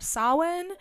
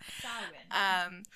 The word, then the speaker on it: Samhain: Originally, I was going to be telling y'all about Samhain. Which I believe I said that correctly. Okay. – I'm so proud of you for saying it correctly.